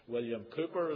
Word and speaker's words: William Cowper: That was William Cowper, as they pronounced it in England.